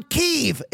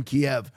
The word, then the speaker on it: Kyiv: I verified with a Ukrainian friend, is that the correct pronunciation, Kyiv? And they said, yeah, it was. I was wrong. I hate that because it's been Kiev for my entire life, and now everybody calls it Kyiv.